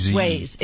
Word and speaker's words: Waze: Is that how it's pronounced? I hear people pronounce it Waze. – Waze. It's pronounced Waze.